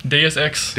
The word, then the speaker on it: Deus Ex: To quote Deuce-X, or however you pronounce that. Deus-X. – Deus-X. – I always say Deuce, like it's 2X. It's the sequel to Deus Ex.